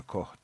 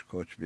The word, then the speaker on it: Koch: Koç biraderler var işte Amerika'nın, yani KOCH diye yazılıyor ama Koç okunuyor, Koh değil yani.